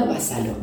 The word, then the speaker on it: Basalo: Lo único que que tener en cuenta es eh, cómo se escribe mi apellido. O sea, es Paula Basalo. No se escribe con B corta, sino con B larga.